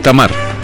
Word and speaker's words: Tamar: Y si lo estoy pronunciando mal me dicen, porque a veces como la T, la H con la A, en inglés es Damar, pero a lo mejor ella es Tamar. Entonces, es Tamar.